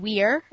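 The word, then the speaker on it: vir: Well, if you take Ananaeus means spider or spidery, and weir, because V's are pronounced as W in Latin, is man.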